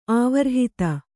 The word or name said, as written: ಆವರ್ಹಿತ